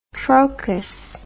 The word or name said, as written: trochus